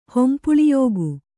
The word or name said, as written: ಹೊಂಪುಳಿಯೋಗು